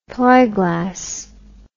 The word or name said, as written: plyglass